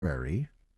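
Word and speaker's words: Atterberg: Utterberry. It's spelled Utterberg, but pronounced Utterberry, just in case you were Googling.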